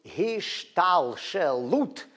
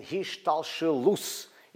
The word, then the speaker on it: hishtalshelut: In Hebrew, it's hishtalshelut. If you are using Sephardic pronunciation, and hishtalshelus, if you're using Ashkenazi pronunciation, literally means devolution.